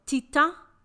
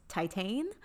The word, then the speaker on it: Titane: It is a little movie called Titan, or if you want to be American and butcher it, it's Titane, directed by Julia Decano, who made the 2016 film Raw, if you are familiar.